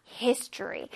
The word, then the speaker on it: history: History. 자, H는요, 부드럽게 날 듯 안 날 듯 발음하고 I 부분을 강조해서 History. History.